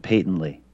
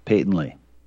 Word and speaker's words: patently: To avoid this odious work camp, I suggest you train your mind to pronounce the word patently correctly with a long A sound, a minimum of 100 times in a row.